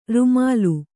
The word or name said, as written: ರೂಮಾಲೆ